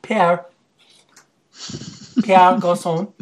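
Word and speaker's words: Pierre Garçon: The best bargains are Edelman, Garcon. I'm sorry, how do you pronounce that guy's name? – Pierre Garcon.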